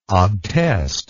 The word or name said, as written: obtest